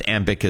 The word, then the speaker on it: Ambiq: And for our listeners, AMBIC is spelled AMBIQ.